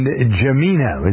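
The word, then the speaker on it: Jimena: Kilo, Ignacio, and Jemina. Is that how you pronounce it? It's J-I-M-E-N-A, not Jemima.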